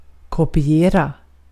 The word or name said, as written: kopiera